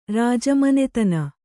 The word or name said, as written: ರಾಜಮನೆತನ